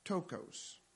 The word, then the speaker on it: ptochos: is tokos, and it's spelled P-T-O-C-H-O-S. And I think it's pronounced tokos.